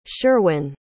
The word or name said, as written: Sherwin